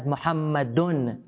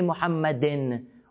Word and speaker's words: مُحَمَّدٌ: That means it's pronounced as Muhammadun. They don't say Muhammadin, or they don't say Muhammadan.